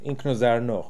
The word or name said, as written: Inkozarno